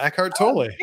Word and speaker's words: Eckhart Tolle: Eckhart Tolle. I'm not sure if I'm reading that right. Did I say that right? – You did, yeah. Eckhart Tolle.